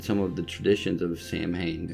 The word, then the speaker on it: Samhain: So without further ado, the tradition of Halloween originated with the ancient Celtic festival of, it's spelled Samhain, like S-A-M-H-A-I-N, but it's actually pronounced several different ways, and I think the American pronunciation is Swain.